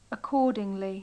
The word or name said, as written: accordingly